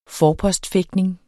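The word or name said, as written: FORPOSTFÆGTNING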